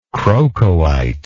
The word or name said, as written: crocoite